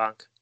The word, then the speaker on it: Ankh: So Danny, asking us via Facebook, asks, can you tell us about the connection between the Egyptian Ankh hieroglyph and the Nephilim? And I'm sure you'll correct my pronunciation of Ankh. – You did pretty well.